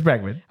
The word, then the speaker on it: Bregman: It's Bregman.